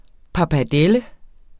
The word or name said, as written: pappardelle